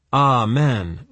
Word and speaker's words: Amen: He pronounced it Amen, rhyming with Brahmin.